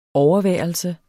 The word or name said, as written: overværelse